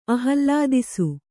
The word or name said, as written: ಆಹ್ಲಾದಿಸು